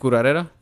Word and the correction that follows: curandera: Curandera. – Curandera.